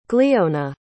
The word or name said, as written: gliona